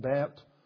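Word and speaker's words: Bet (בית): So the first part of the name, or the word Bethel, is actually referring to the Hebrew letter B, which is pronounced Bet and means house.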